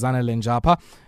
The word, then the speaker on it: Zanele Njapha: Her name is Zanele Njapha, and she is the founder of Zootiful.